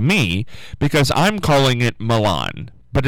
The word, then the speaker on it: Milan: But we had a gentleman call in right before I went on the air, said he grew up in that area, and it's pronounced Milan, not Milan. It's Milan. So,